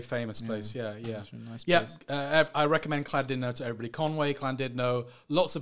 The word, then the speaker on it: Llandudno: Llandudno, yeah. Welsh, so they pronounce it Llandudno.